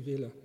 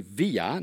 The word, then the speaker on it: Villa: and Ricky Villa, not strictly speaking pronounced Villa, though some did, for Spurs in 1981, and Brooking scored the winning goal for West Ham in 1980.